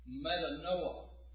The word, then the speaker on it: metanoia: In the New Testament, the Greeks use a word, metanoia. I think that's the way you pronounce it. M-E-T-A-N-O-I-A.